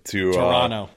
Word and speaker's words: Toronto: Toronto. I can't believe I pronounced the T. That is, I should get docked major points for that. That was bad.